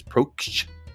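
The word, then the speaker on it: Proksch: How do you say that? It's P-R-O-K-S-C-H. Prokst, I guess.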